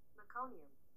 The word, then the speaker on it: meconium: So, real quick, how do you say that word that I'm not sure about? – Meconium. – Oh, I meconium. – Knew that. Meconium.